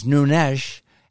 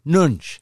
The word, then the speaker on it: Nunes: I welcome the CEO and guru of Truth Social, Devin Nunes, correctly pronounced in the Portuguese, Nunes, and in Portugal itself, Devin tells me, Nunes.